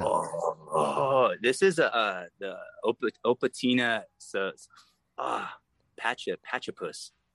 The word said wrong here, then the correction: Opuntia: Opuntia, yeah.